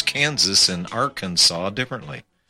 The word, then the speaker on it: Arkansas: Since the letter S at the end of the French words is usually silent, we pronounce Billy Clinton's home state as Arkansas.